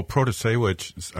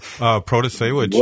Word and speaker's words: Protasiewicz: Well, Protasiewicz, I got to say it right there. Protasiewicz. – Well done.